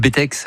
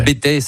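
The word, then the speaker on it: Bettex: Oui, alors juste au-dessus de Saint-Gervais, où effectivement, il y a le Bétex. Alors le Bétex, certains disent, prononcent pas le X, le Bétex, enfin il y a deux écoles,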